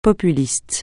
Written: populiste

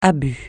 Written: abus

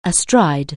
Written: astride

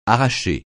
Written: arracher

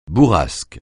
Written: bourrasque

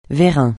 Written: vérin

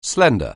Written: slender

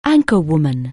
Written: anchorwoman

